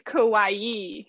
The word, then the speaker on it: kawaii: Actually, it's kawaii. – Kawaii. I thought I was pronouncing kawaii. Kawaii. So the two I's are an E. Just imagine it as an E.